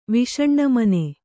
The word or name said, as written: ವಿಷಣ್ಣಮನೆ